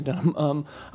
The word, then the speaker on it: Dunham: And I was skeptical of Lena Dunham, Lena Dunham? Why am I pronouncing it like that? Lena Dunham.